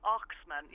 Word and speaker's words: Oxman: And what I didn't realise was the, am I saying this right, the Oxyman? – The Oxman, yeah, no, E-I, so it's O-X-M-A-N. Oxman.